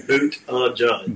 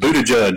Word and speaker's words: Buttigieg: You just say Buttigieg. Buttigieg. As fast as you can.